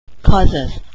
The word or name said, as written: causes